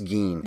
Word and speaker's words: Gein: which is so close to Ed Gein, how do you say it? – I think it's Gein.